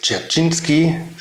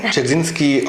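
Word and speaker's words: Dzerzhinsky (Дзержинский): Das sind Denkmäler aus dem Stalinismus, aus der DDR-Zeit, Felix Dzerzhinsky, richtig ausgesprochen? – Ja, Dzerzhinsky aus dem Stasi-Gelände, sozusagen der Gründer sozusagen des KGB.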